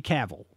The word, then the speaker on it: Cavill: And it is Henry Cavill. It is not Cavill.